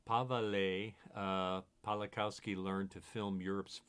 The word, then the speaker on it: Paweł: We would say his first name is Paweł. It's spelled P-A-V-E-L, but in Polish, Paweł Palakowski.